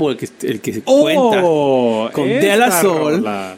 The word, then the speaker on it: De La Soul: Es en inglés. Se pronuncia en inglés. De La Soul. De La Soul. – De La Soul. – Meten una W ahí en medio de la.